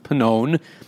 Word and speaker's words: Pannone: Perennial Cy Young contender Chris Sale against Cranston, Rhode Island's very own Thomas Pannone, rhymes with unknown.